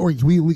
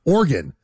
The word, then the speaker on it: Oregon: Like if you're doing the Oregon, can we say it? It's Oregon.